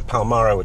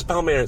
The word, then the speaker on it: Palmyra: And my dad was a sort of amateur archaeologist, and we'd go to Damascus, and then we'd go to Palmyra, which is my favourite place on earth. – I'm loving your correct pronunciation, because I would have probably said Palmyra. – Everyone says Palmyra.